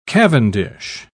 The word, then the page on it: cavendish: British English